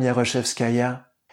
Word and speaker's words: Yaroshevskaya: Je lui dis tout simplement, bonjour, Madame Yaroshevskaya.